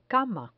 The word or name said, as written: cama